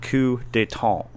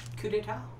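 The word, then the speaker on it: coup d'état: So today we're going to talk about the word coup d'etat. Did I say that right? – Pretty close. – I mean, you're a well-traveled man. – We always say coup d'etat, but I know, yeah.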